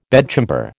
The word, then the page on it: bedchamber: American English